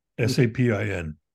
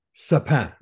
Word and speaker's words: Sapin: But this year we found one from Diptyque called, pronounce it, Sapin. – Sapin.